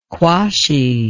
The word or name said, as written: quashee